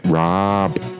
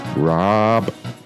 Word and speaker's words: Raab: Dominic Raab is how you pronounce his name. Raab.